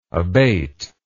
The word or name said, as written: abater